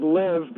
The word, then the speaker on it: LIV: And so LIV, by the way, is a Roman numeral for 54. And so we pronounce it LIV because what are we else going to do? – It was right.